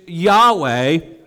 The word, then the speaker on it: Yahweh: which most Hebrew scholars believe is probably most approximated with the pronunciation Yahweh,